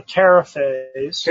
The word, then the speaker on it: Terifay: Now, when I found out about the Terrafays, can you spell that last name? – T-E-R-I-F-A-Y. – T-E-R-I-F-A-Y? – Yes, Terrafay. Okay.